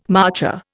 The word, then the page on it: marcher: Indian